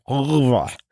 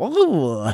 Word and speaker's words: oeuvre: And he said, Oeuvre. And I said, wait, say it again? And he looked up to me and said, Oeuvre.